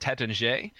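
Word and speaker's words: Taittinger: And whenever I said Tétanger, or it's spelled Tattinger,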